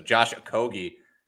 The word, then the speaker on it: Okogie: Shout out to Chris Smith, who apparently listens to the podcast, said we had a Okogie's name right the first time. It's a hard G. So Josh Okogie, sorry for not heeding your advice the first time, Chris.